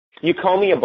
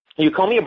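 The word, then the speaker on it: Eucommia: Euchomia bark. Euchomia bark is very popular in Chinese medicine.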